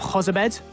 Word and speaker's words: Jozabed: And we're going to discuss both those topics, plus we'll also examine the departure of Jozabed. Uh, have I pronounced that all right? Yep.